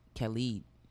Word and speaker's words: Khalid: Yo, Khalid.